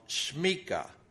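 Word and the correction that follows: semikha: if I'm saying it correctly, or samika. It's actually samika. It's a samik. It's not a sheen. It's samika.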